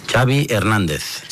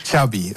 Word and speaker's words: Xavi Hernández: What is the correct pronunciation of your first and last name? – Xavi Hernández. – Xavi, okay. – Yeah. So I heard the... Xavi like chocolate.